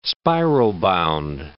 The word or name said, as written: spiral-bound